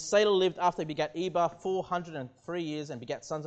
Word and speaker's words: Eber: If you remember, I took you to the New Testament where Eber is pronounced Heber, and that's where we get the term the Hebrews from, okay?